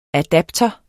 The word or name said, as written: adapter